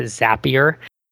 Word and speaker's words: Zapier: I'm a big fan of Zapier. And you know what I found out? I talked to Wade, who's the founder, and he said they pronounce it Zapier.